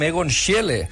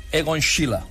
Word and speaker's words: Schiele: És un quadre fascinant d'un pintor que nosaltres acostumem a pronunciar com Egon Schiele, però he estat mirant aquests dies i per fer-ho bé, per pronunciar-ho, diguéssim, en austríac, hauria de ser Egon Schiele.